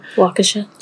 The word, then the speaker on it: Waukesha: Waukesha? Did I say that right? – Waukesha.